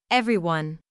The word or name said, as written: everyone